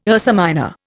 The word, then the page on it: Ursa Minor: Indian